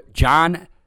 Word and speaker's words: Jon: Follow us on Twitter at Weisbrod John. That's at W-E-I-S-B as in boy, R-O-D as in dog, J-O-N.